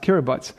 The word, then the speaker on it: Kiribati: It's spelled Kiribati, but it's pronounced Kiribati.